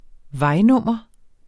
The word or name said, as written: vejnummer